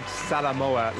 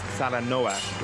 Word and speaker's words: Salanoa: Well, you said Salamoa like the country Samoa. It's Salanoa like the ark.